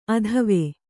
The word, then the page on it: ಅತ್ವ: Kannada